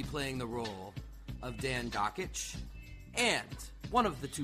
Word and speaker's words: Dakich: Not Dakich, Dockich, rhymes with Jockich.